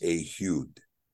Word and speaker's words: Ehud: And this has to do with, for instance, if you saw the name Ehud, it's not Ehud, it's Ehud, Ehud. Like Ehud Barak, right? He doesn't call himself Ehud, he calls himself Ehud. And it has to do with the fact that the ayin is pronounced as A.